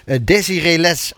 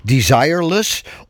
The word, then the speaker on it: Desireless: Waarvan ik me afvraag of het niet moet uitspreken als het Engelse desireless. Of meer het Frans klinkende Desiree Les. – Ik dacht desireless was het de bedoeling. Maar op zijn Engels.